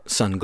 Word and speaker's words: Sunn: Sunn is the band, and that's S-U-N-N with an O and three parentheses at the end of it. That is the Sunn glowing there, but it's just pronounced Sunn, S-U-N-N.